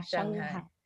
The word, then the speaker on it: Shanghai: I hope I'm saying it right. Shanghai, right? – Yeah, Shanghai. – Shanghai. Okay. All right, good.